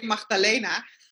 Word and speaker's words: Magdalena: Is that how you pronounce it? – Yeah, that would be great. I say Magdalena.